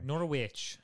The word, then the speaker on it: Norwich: Why did I pronounce it? It's Norwich. – Norwich.